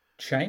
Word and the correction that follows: Chani: It's Chani. They're probably pronouncing it in a way that makes it sound a bit less.